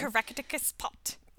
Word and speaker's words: Caractacus Potts: Like, Caractacus, how do you say that name? – Caractacus Pot.